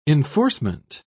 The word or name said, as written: enforcement